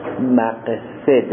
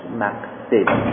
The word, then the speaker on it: مقصد: رفقا در کفایه 8 مقصد هست. 8 مقصد. شما میگید مقصد، من میگم مقصد. تلفظ درستش مقصد هستن، به غلط مقصد در زبان ها جا افتاده.